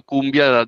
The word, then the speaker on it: Koombea: Como, como, tú decías, el nombre sale del, diccionario cumbia de la palabra es la pronunciación, la, forma como lo escribimos es la pronunciación fonética del baile, del baile cumbia,